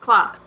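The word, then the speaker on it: Klaue: Can we just say, I think I'm going to say his name right, Claw? – Yeah. Yep. – Claw.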